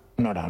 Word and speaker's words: Norah: ¿No cambia la pronunciación o qué? – No cambia. – Norah, ¿no? – Norah.